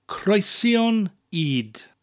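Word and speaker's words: creision ŷd: Cornflakes in Welsh is croesion id. Two words, croesion, spelled C-R-E-I-S-I-O-N, and id, which is spelled Y-D, and the Y has a little accent on it, an upside down V, creating an elongated E sound.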